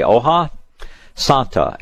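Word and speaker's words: hoja santa: H-O, I believe it's H-O-J-A. Oha Santa, S-A-N-T-A.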